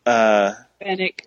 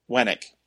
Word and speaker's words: Fennec: Fennec. – Beckett? Bennett. – Ben. – Fennec. – Fennec. Fennec. Yeah. Fennec. Wennec.